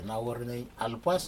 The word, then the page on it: nawuranig alpas: Aneityum